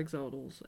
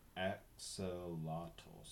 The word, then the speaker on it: axolotl: They are aggressive and treat other aquarium habitats, including smaller axolotls, as food. So, no. – Oh, my God. – Oh, here's the pronunciation. Axolotl.